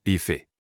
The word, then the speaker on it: Ife: So, he jumped onto a hill, looked around and decided to name that place Ife, pronounced Ife.